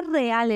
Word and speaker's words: real: Real se escribe igual, pero ¿cómo se pronuncia, Real. Otra vez, ¿cuál es la clave? La R. Es que la R es la protagonista.